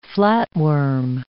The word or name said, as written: flatworm